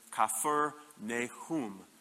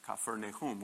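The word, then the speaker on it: Kfar Nahum: And that's because when you pronounce uh, the pronunciation in Hebrew of Capernaum is Kafir Nehum, Kafir Nehum, which means the village of Nehum, or the village of comfort.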